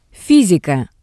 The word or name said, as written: fysika